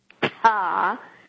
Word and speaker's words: Ptah: My tongue is like that of Ptah, P-T-A-H, which is pronounced like a spit.